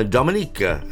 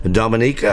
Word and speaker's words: Dominica: Tom Drake, we have just arrived in Dominica, and that's how it's pronounced, not Dominica. Dominica.